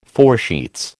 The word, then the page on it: foresheets: American English